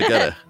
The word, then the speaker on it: Egede: Egede. – Egede. – Egede. – So I was hard. I was right with the hard G. – Yeah, you're right. It's hard.